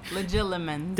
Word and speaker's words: Legilimens: The theory for Snape's heritage stems from Quinny's excessive gifts, abilities as a legitimate. I'm going to say it wrong. I know I'm saying it wrong. – Legilimens. – Thank you.